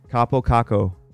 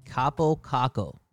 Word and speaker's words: Kaapo Kakko: Kaapo Kakko. – Kaapo Kakko.